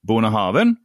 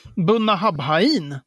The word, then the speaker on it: Bunnahabhain: Bonahaven.